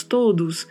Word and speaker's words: todos: Em espanhol, não. É todos, todos. O sempre fechado e a letra D, a gente dá uma suavizada na pronúncia. Não vamos pronunciar tão marcado, não vamos falar todos. Não, todos.